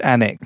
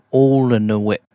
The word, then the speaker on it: Alnwick: And it's called Annick, but you'd never be able to spell it by the way it's pronounced. It's like Alnwick, Alnwick.